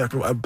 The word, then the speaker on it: AHI: I'm Odario Williams. We're at TD Music Hall in Toronto with Akinoha Haba Azar, better known by his initials, A-H-I, simply pronounced I.